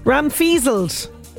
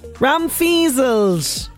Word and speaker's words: Ramfizzled: Okay, so no surprises, but I've been pronouncing the mystery word wrong. I'm so sorry. Ramfizzled. Ramfizzled. That's the correct pronunciation.